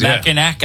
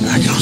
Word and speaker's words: Mackinac: When he goes to Mackinac Island? – Oh, no, Mackinac Island.